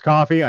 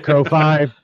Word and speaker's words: Ko-fi: It's pronounced coffee. I know it looks like Ko-Five. It's pronounced coffee.